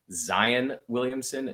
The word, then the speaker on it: Zion Williamson: his name is actually supposed to be pronounced Zion Williamson, not Zion Williamson, but that he prefers for the announcers to say Zion Williamson uh, because it sounds cooler.